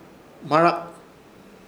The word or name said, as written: Hmarak